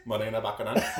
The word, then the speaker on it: Morena Baccarin: Well, how do you pronounce it? – Morena Baccarin. No? – Yeah. – Or as white people would call her, Morena Baccarin. – Baccarin.